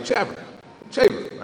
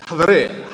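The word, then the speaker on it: Haver (חבר): So the word is spelled C-H-A-B-E-R. I was like, Chaver, right? It's Haver, Haver. You know, when you speak Hebrew, Haver, right? So, Haver.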